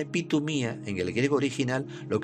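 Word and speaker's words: epitumía (ἐπιθυμία): Y es una palabra griega que se pronuncia epitumía.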